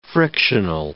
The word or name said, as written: frictional